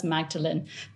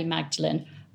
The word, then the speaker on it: Magdalene: Here are some facts about this Tudor lady, and I'm going to call her Magdalene, as I'm assuming it was pronounced as Magdalene because of Mary Magdalene, rather than Magdalene like the Oxford College.